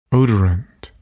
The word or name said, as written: odorant